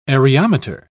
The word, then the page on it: areometer: British English